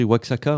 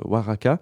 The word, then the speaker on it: Oaxaca: Alors ça s'écrit Oaxaca, hein, mais on est très fort en mexicain, donc on prononce Oaxaca.